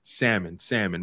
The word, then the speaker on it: salmon: The L is silent. Salmon.